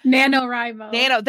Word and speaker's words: NaNoWriMo: I lost a whole lot of, I gave up on Rimo Nano, right? Is that right? Doesn't even sound right in my head anymore. – NaNoWriMo. – That's right, because I said it wrong.